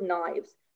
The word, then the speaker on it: knives: And the word in the book that the kid had to get was the word knives. Now, why in the early levels would you put a silly word like with a silent K at the beginning when they haven't even learned that in phonics yet?